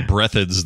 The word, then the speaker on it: Breathed: I don't know any other Breatheds than him.